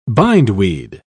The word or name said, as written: bindweed